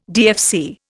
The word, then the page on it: DFC: Indian